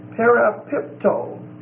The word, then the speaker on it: parapipto: This word is very much akin to the word parapipto. P-A-R-A-P-I-P-T-O. And that O is a long O.